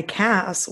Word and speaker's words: Cass: And I will say, though, I've always gone by Cass, which clearly rhymes with ass, right? – Oh, no.